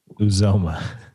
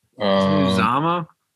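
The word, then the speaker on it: Uzomah: How do you say his last name? – Uzoma. – Uzama? This is like a P. Ryan thing with you. Like every announcer calls him Uzama. You're Uzoma.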